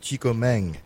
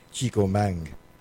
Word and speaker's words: Chico Mang: I'm going to start this next set off with some newer Latin-influenced tunes, starting with Chico Mang. Spelled Chico Man, but they say it's spelled, you pronounce it Chico Mang.